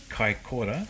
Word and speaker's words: Kaikoura: Kaikoura.